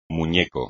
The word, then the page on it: muñeco: espagnol